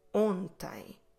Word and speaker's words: ontem: The word for yesterday in Portuguese is ontem. Ontem. Como se escreve ontem? O-N-T-E-M. Ontem. So, yesterday I walked.